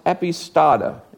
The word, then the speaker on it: epistata: It's pronounced epistata. It almost sounds like stot, but it's not. Epistata, E-P-I-S-T-A-T-A.